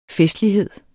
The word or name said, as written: festlighed